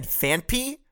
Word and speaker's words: Phanpy: Yes, I said Phanpy. That is how you pronounce it. Look it up.